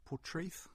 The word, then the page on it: Portreath: English